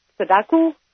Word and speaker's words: Sudoku: And also the Sudoku.